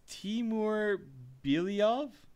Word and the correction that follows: Timur Bilyalov: I guarantee once you hear it, it's going to be, like, so easy to pronounce. – Oh, Timur Bilyalov? – Yeah, that sounds better than Bilyalov? – Jake, here, clearly, yeah, that's a little better than my attempt.